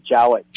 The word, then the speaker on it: Jowett: Oh no, you're saying it right, Jowett. – Jowett.